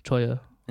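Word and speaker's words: Cholla: Well, I think you would pronounce it the double L Y, Choya. – In Spanish.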